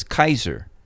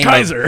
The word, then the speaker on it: Caesar: So when he talks about Caesar, he says Kaiser. Because that's actually the appropriate pronunciation.